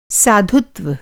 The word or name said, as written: साधुत्व